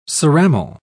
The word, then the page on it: ceramal: British English